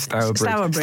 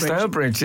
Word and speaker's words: Stourbridge: Mel from, how do you say this, Frank? Is it Stourbridge? – Yes, Stourbridge. Stourbridge is where Jude Bellingham comes from.